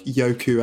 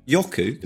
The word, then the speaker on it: Yoku: The reason I say Yoku is because that's how you'd say it if it was Japanese, and it felt Japanese to me, because those are two Japanese characters, Yoku.